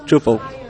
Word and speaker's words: Drupal: Drupal. – Drupal, not Drupal. – No. – Not like the drag singer, like RuPaul. – No, Drupal is not a drag queen. – What do you see as Drupal's greatest strengths and weaknesses?